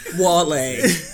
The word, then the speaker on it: Walé: I pronounce it like how I feel Beyonce is gonna be like Shatta Walé. Shatta. Shatta. – You know how like she says Onika on the feeling myself. She'd be like Shatta. – That, so that, how do you guys feel? Accent on the E.